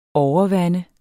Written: overvande

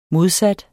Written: modsat

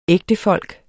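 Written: ægtefolk

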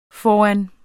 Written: foran

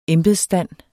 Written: embedsstand

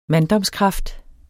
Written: manddomskraft

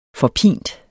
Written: forpint

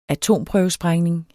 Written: atomprøvesprængning

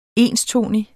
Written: enstonig

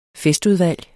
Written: festudvalg

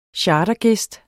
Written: chartergæst

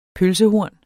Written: pølsehorn

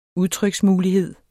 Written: udtryksmulighed

